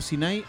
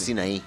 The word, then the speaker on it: Sinaí: ¿Cómo se dice? ¿Sinaí o Sinaí? – No, Sinaí. Sinaí. Monte Sinaí. – Con acento en la N.